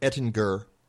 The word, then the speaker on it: Ettinger: But we had Albert Ettinger with a hard G on the show last week,